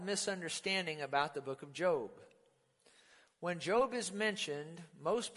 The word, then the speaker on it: Job: Now, for those of you who are not familiar with the book of Job, and it's pronounced Job, not Job, but Job, it is about a righteous and prosperous man who is attacked by the devil.